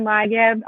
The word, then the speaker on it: Leigeb: It's at Allison Leigeb, two L's and LEIGEB.